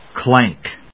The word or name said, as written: clank